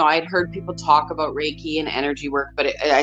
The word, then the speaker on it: Reiki: So Reiki.